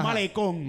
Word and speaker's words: malecón: Malecón. – Malecón. Tiene malecón. Acento en la O.